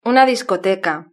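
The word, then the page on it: una discoteca: Spanish (Spain)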